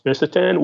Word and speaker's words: fisetin: I think fisetin, or sometimes pronounced visetin, would be, second, but not quite really even a close second.